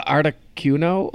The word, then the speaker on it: Articuno: I'm gonna say it wrong because I pronounce all Pokemon wrong. Uh, Articuno. Okay. – big decal of Articuno above the bed.